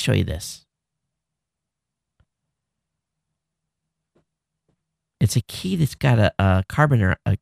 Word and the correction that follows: carabiner: A carabiner, that's the correct pronunciation.